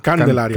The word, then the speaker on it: Candelaria: So we'll be talking that in a little bit, but I'll give you the name is Maria Calendaria, right? – Candelaria. – Candelaria. Yeah, I said it wrong a couple of times. I kept saying calendaria like calendar.